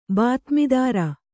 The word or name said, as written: ಬಾತ್ಮೀದಾರ